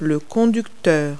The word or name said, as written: driver